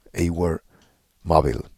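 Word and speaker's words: Awer Mabil: Él me dijo que es Auermaville. su nombre o su pronunciación correcta.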